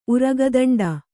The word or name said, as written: ಉರಗದಂಡ